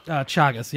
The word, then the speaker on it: Chagas: Against Luan Chagas? – Chagas, yeah.